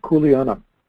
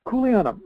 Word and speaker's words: Kuleana: It's spelled K-U-L-E-A-N-A, for those who are listening. Kuleana. Kuleana.